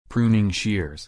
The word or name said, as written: pruning shears